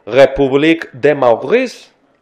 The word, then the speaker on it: République de Maurice: République de Maurice. Lo dije bien, vos francés. Sí, sí, súper Perfecto, perfecto.